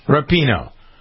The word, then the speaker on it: Rapinoe: Rapinoe.